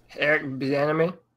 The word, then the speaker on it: Bieniemy: Eric Bieniemy.